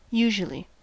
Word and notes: usually: American English

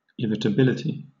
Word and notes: irritability: British English

